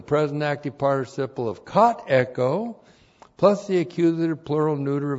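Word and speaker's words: Echo (ἔχω): E-C-H long O. Echo.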